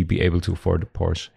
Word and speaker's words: Porsche: It's what Porsche, or as the Americans say, Porsche.